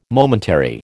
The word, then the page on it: momentary: American English